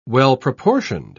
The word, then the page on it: well-proportioned: British English